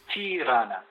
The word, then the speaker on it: Tirana: Tirana. – And I'm going to have you say the capital one more time. You'll notice how I cleverly managed to avoid saying it the first time. Tirana? – Tirana, Tirana.